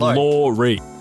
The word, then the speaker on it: Lawrie: Lawrie. – John. – Which John? – Me. Yeah, which John? – John Lawrie. – Oh, John, okay, the Lawrie. So it's pronounced Lawrie? – It's pronounced Lawrie. Like Lawrie.